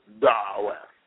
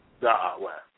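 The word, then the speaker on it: da'wah (دعوة): But the correct pronunciation of our call or invitation is da'wah, da'wah.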